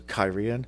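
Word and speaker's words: Cairhien: So, the way this particular city is pronounced is Kyrian. So, Kyrian.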